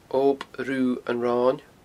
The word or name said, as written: Ob Rubha an Roin